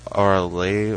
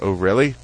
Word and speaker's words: Orly: It's probably Orly or Orly or something, but it's spelled O-R-L-Y, so... Every time we went into the metro, we're like, hey, can we take this train to O'Reilly?